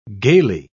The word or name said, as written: gaily